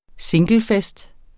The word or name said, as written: singlefest